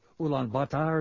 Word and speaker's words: Ulaanbaatar: If you're listening to us in Ulaanbaatar, or Ulaanbaatar is the way you say it properly, on the Internet, and you want to join the conversation, the best way is via email.